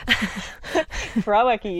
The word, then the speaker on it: Krawecki: Did I say that right? I'm always like, every time I'm going to say it, I'm like, I'm going to mess it up. – Krawiecki, you did a great job.